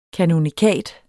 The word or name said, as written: kanonikat